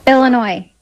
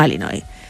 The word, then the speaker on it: Illinois: Él, español, ella estadounidense, concretamente de Illinois, y fue quien grabó este audio para que escuchemos cómo se dice realmente. – Aquí te doy la vuestra. – Illinois. – Vamos a poner la parte corta. – Illinois. – Illinois. No Illinois.